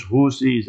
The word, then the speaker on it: Houthi: The word is Houthi with a Tha, not Houthi as the Westerners pronounce it.